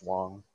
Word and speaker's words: Wang: It's W-A-N-G, so you think it's like Wang, but it's Wong.